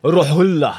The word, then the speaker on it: Rohulla: Nej, den var bra uttal. Lägg in lite mer Rohulla.